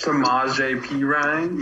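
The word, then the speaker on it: Samaje Perine: Sanjay Perine guy. Sam Samaj Samaj P Ryan.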